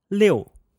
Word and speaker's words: liu: So the pinyin liu with a fourth tone will sound like liu.